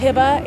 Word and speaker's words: Heba: Hiba, H-E-B-A. It's a first name, the last name is Nimmer, N-I-M-R.